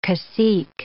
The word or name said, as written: cacique